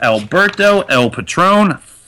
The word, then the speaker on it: Alberto El Patron: Next, Rusev making his tournament debut against Alberto El Patron.